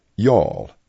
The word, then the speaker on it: y'all: Bless your heart, appropriately decreed for a variety of situations, and y'all, pronounced as one syllable.